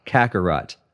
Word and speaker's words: Kakarot: Kakarot.